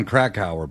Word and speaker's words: Krakauer: Now, the book, John Krakauer book, that's the correct pronunciation, right? – Yeah.